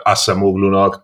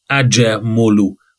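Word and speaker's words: Acemoğlu: Kamer Darren Acemoglu, úgy kell mondani a nevét. Tehát a J-vel és a Mogluban a G betű nincs benne. Tehát aha, a kiejtés legalábbis kiejtés szempontból. Acemoglu.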